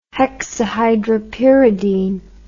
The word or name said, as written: hexahydropiridine